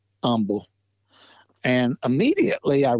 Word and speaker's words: Humble: But in my research, I kept reading about Michael Humble, and it's actually pronounced with the H being silent, Michael, or Humble, not, it's spelled H-U-M-B-L-E, and the H is silent. So you, when you read it, you're going to say Humble, but the family is always pronounces it without the H.